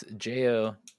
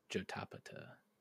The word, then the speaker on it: Jotapata: Jodapa. No, what is it? How is it spelled? – It's Jotapata. Jotapata.